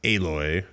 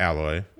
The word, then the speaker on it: Aloy: It's pronounced Aloy, not Alloy.